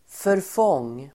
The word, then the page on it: förfång: förf'ång: